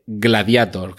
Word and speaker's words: Gladiator: que por cierto digo Gladiator y no Gladiator, porque Gladiator es una palabra en latín y como tal hay que pronunciarla. La A en latín es una A. Otra cosa es que en inglés esa A siga diga EI.